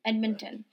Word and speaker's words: Edmonton: Edmonton.